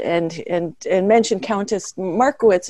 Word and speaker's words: Markievicz: Uh, Markievicz, if I'm saying that correctly.